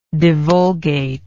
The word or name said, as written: divulgate